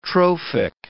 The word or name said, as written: trophic